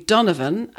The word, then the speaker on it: Donovan: In Ireland, we pronounce the name Donovan as Donovan, D-U-N-N-A-V-U-N, even though it's spelt D-O-N-O-V-A-N, Donovan. And it comes from a mix of the Irish for brown, which is down, D-O-N-N, and the Irish for dark black, which is dove, down dove, Donovan.